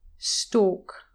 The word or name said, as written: stalk